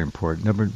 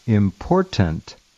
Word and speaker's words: important: Number two, he's one of these people, again, and I've heard maybe half a dozen recently, who cannot, will not, and do not pronounce the word important correctly. They leave the T out, and they say important.